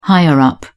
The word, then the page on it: higher-up: British English